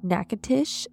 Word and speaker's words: Natchitoches: Michael Allen Lacaze was born on July 5th, 1955, just south of Natchitoches, Louisiana. – Is that pronunciation correct? – Yes, I wrote it out phonetically because it is spelled not like that.